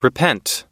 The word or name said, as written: repent